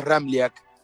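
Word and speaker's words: Ramljak: Es un poco complicado porque tiene tres consonantes juntas y la J se pronuncia como una I. Bien pronunciado, el apellido es Ramliak.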